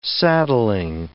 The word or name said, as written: saddling